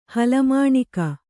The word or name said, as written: ಹಲಮಾಣಿಕ